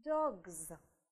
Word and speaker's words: dogs: Dogs. В этом случае окончание «s» будет произноситься как звонкое «z», потому что ей предшествует звонкая согласная. Да? То есть мы будем произносить звук «з».